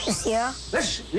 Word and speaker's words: Lisieux: Lisieux. – Lisieux is right. Good. Do you speak French? No. You sound like you speak French. You pronounced that perfectly.